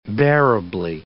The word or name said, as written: bearably